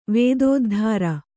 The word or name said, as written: ವೇದೋದ್ಧಾರ